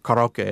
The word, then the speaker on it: karaoke: I mean, but he doesn't even own the rights to the word karaoke, or karaoke, as it's pronounced in Japan.